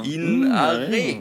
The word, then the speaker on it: Ina Regen: Du musst, du musst kein stummes Haar dazu dichten. – Du kannst doch einfach so, genauso wie es ist, einfach halt nur ein bisschen im Dialekt aussprechen. – So. – In Are. – Okay. Ja. – Hast kein Buchstaben dazu.